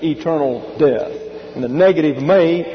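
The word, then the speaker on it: may (μή): This is a long E in the Greek pronounced like the English A. So you have, it's transliterated me, but it's pronounced may. Then you have another negative, ouk. O-U-K.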